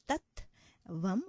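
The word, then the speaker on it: తత్త్వం: The pronunciation is tattvam with a soft v.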